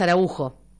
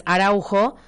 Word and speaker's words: Araujo: En Uruguay es Araujo. El apellido que acá en Argentina es Araujo. En Uruguay se pronuncia Araujo.